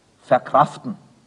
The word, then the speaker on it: verkraften: Wie wird nun das E ausgesprochen in der Vorsilbe des Wortes verkraften? Wenn Sie es genau wissen wollen, überhaupt nicht.